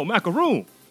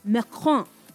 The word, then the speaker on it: Macron: Oh, macaroon. Oh, I love me some macaroons. – No, Macron.